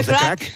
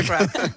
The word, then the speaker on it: craic: What's the crack? – What's the crack? – What's the crack? – Es como el how are you, pero en irlandés, ¿vale? – What's the crack? – What's the crack? – The crack is good.